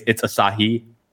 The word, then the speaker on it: Asahi: But like Simon, if you're gonna talk about that 58 2.4 lens, please pronounce it properly. It's Asahi.